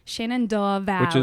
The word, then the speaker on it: Shenandoah: Shenandoah Valley. – Which is how you say it when you're from here. – Exactly. – With a hard O-R on the end, apparently.